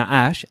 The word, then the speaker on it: Ash: She's like, I'm like, no, Ash, A-S-H.